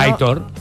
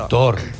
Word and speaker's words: Aitor: Es un oyente que dice, por favor, que Aitor se pronuncia como se lee. – Sí, no. Aitor. – Aitor. Dice, Aitor. Dice Aitor. La acentuación es en la O.